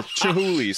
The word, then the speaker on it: Chihuly: Does he know Jim Gahooly? Dale Chihuly, sorry.